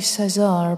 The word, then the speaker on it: Cesar: There's a baptismal record in St. Hilda's Church, and it's just one line, and the entry says, Caesar, C-E-S-A-R, so it might be Caesar, but I'm pronouncing it Caesar.